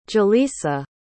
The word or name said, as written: jaleesa